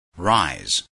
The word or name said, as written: rise